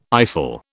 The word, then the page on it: eyeful: British English